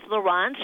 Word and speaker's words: Lawrence: You know, it's Lawrence. She said it rhymes with romance.